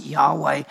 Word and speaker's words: Yahweh: We sometimes translate it or try to pronounce it Yahweh, but it means the personal name of God.